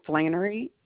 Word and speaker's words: Flannery: is Flannery, F as in fish, LANNERY.